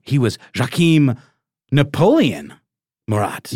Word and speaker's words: Joachim: I think in French it's Joachim, something like that. – Yeah, Joachim. Yeah. Okay. – I think the J is a little more apparent. – A little more pronounced.